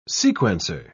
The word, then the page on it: sequencer: British English